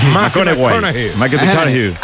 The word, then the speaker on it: McConaughey: Me fichó, le gusta que hay que ir corrigiendo la pronunciación. McConaughey.